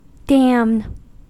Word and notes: damn: American English

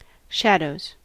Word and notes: shadows: American English

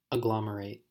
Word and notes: agglomerate: American English

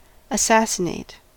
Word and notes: assassinate: American English

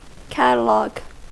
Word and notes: catalogue: American English

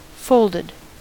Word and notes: folded: American English

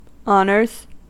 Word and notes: honors: American English

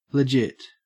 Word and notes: legit: Australian English

